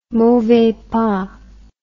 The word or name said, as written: mauvais pas